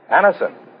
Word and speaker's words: Anacin: It's spelled A-N-A-C-I-N. Anison.